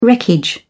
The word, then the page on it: wreckage: British English